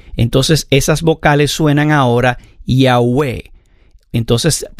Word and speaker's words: Yahweh: En hebreo, ellos lo escribían con 4 letras, YHWH, el tetragramatón, que últimamente le pusieron unas vocales para poder pronunciar estas 4 letras, YHWH, para que sonara como Yahweh.